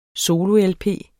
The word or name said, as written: solo-lp